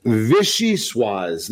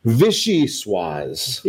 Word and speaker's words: Vichyssoise: Vichyssoise. They actually have it put right in here how you pronounce it. Vichyssoise.